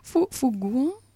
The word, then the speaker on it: Faugouin: Fougouin, vous le prononcez comment? F-A-U-G-O-U-I-N.